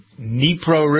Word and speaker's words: Dnipro: A large barge loaded with weapons that was blown up yesterday on the Dnipro River. Is that how you pronounce it? D-N-I-P-R-O.